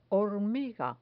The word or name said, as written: hormiga